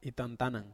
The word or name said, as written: tantanan